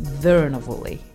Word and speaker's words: Curuenavuli: It's spelt C-U-R-U-E-N-A-V-U-L-I, but it's pronounced Thurnavulli.